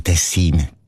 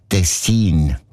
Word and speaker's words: Tessin: Also klar, Tessin. Aber der SRF-1-Hörerin Regula Stern-Grieser von Locarno ist aufgefallen, dass gewisse Leute statt Tessin Tessin sagen, also Betonung auf ein Meh, mit einem kurzen I.